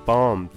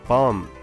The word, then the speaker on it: bomb: It's been bombed several times. Bomb 炸弹，那它被轰炸过。那记得这个字的 b 后面那个 b 不发音，叫做 bomb，b o m b，bomb。